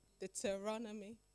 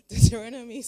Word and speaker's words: Deuteronomy: As we know in the book of Deuteronomy, that's how we say it. Deuteronomy. I'm in Oxford, so I have to say it properly. Praise God. in the book of Deuteronomy 6,